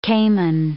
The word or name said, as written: cayman